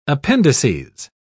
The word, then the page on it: appendices: British English